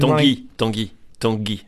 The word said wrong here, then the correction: Tanguy: and Ndombele. – Dongi. Okay. – Dongi. – Dongi.